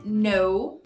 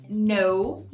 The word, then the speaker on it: know: E last but not least, eu escolhi o K mudo, silent K. Na palavra conhecer é know, know.